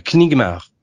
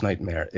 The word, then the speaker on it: Nightmare: Nightmare euh, Knigmar. Ah, ouais. Knigmar. ça s'écrivait. Je le prononçais comme ça. Ça se prononce Nightmare.